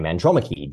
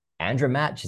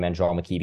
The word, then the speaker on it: Andromache: Andromache because if you look at the way it's it's a hard name to spell phonetically. You go, is that Andromatch? Is it Andromachi?